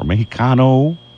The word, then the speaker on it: Mexicano: Acapulco Restaurante Mexicano, or Mexicano. Maybe that's the correct way to pronounce it, Sam? – Yeah, that is.